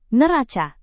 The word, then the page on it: neraca: Indonesian